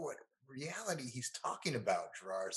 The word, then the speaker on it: Giroir: Giroir. – Thank you. – Giroir, yeah.